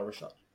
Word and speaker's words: Richat: How do you spell that? – R-I-C-H-A-T. – Oh, Rishat.